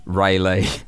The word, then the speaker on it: Raleigh: It is pronounced Rayleigh.